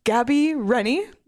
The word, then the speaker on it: Gabi Rennie: The nominees are Gabi Rennie. Is that how you say her name? – Yep.